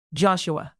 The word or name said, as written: joshua